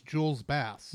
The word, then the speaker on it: Jules Bass: Julie's Bass? – I think it's Jules Bass.